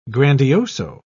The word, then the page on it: grandioso: British English